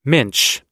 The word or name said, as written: minch